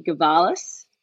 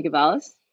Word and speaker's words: Gavalas: So Ellie Gavalas, or at Ellie Gavalas.